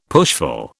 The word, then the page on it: pushful: British English